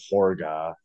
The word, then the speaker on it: Hårga: It's spelled like Hårga, but they pronounce it Hårga in the movie.